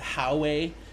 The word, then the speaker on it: Huawei: Some people pronounce it Huawei, and they've done a lot of tablets and e-reader based technologies.